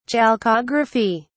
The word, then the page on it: chalcography: British English